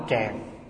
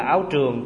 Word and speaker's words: tràng: Và chư tổ Việt Nam đã chế cái áo này thành một cái áo dài, chúng ta gọi là áo tràng. Tràng là dài, có khi đọc là áo trường cũng được, bởi vì trường là dài, tràng là áo dài.